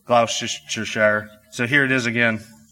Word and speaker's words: Gloucestershire: Gloucestershire. Gloucestershire. Lollards. Lollards. Now you see that was not just the proper pronunciation, but also in the beautiful accent that makes it sound so nice.